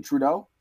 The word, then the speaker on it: Trudeau: Is it Trudeau? – Yeah.